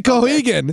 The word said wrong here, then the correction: Keoghan: Did I say that right? I don't think so. – I don't think you did, but now I'm blanking on how to actually say it. – Keoghan. Barry Keoghan.